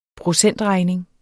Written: procentregning

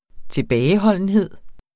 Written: tilbageholdenhed

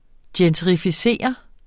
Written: gentrificeret

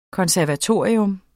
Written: konservatorium

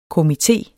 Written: komité

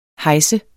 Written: hejs

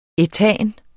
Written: ætan

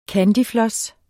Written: candyfloss

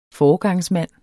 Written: FOREGANGSMAND

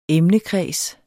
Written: emnekreds

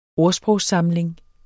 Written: ordsprogssamling

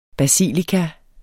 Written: basilika

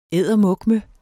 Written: eddermukme

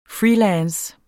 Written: freelance

